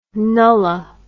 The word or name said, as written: nulla